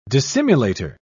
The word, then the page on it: dissimulator: British English